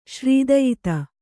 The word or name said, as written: ಶ್ರೀದಯಿತೆ